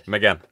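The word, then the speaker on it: Mégane: I don't know how you say it. Megane. Megane means like broken in Quebecois French, I think. But okay, Megane. – Right, Megane. That's right. Right, and we could see.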